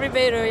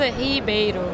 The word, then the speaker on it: Ribeiro: Ana Ribeiro, yes, right. You can say in Portuguese, Ribeiro. – Okay. – You don't have to do that. It's okay. Ribeiro is just totally fine.